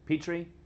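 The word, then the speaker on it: Pitre: And also The Jewish Roots of the Eucharist by this guy named Brant Petrie, P-I-T-R-E. I think it's how you spell it. It's P-I-T-R-E.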